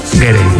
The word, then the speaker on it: Gere: Pensando yo que si te apellidas Ger, bueno, ¿cómo se dice Ger o Gerde? – Gere. Me ha hecho gracia porque has dicho Richard Ger y luego el hijo era Gere. – Yo creo que es Richard Gere.